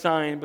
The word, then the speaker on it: Sen: It's pronounced Sein, but it's written in English, S-E-N.